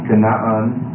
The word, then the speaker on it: Kanaan: You have to get used to pronouncing these the Hebrew way. Kanaan is Canaan. You see why they got the two A's in the English transliteration. And because you have a glottal stop on the I in there.